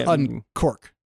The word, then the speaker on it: Unqork: Unquork, which is spelled UNQORK, raised $51 million. – Quark. – A quark is a quantum thing. That's Quark. Quark. – Mm-hmm. – No, this is Unquark.